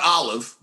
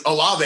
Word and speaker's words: Olave: It's not Olive. That's what you put in a martini. But I think Olave, I think, is how you pronounce his name.